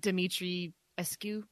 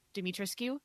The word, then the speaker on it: Dimitrescu: Anyway, so her name is Lady I'm going to mispronounce this, Dimitrescu. I guess it's supposed to be like Dimitri Escu, Dimitrescu. And it was actually her name.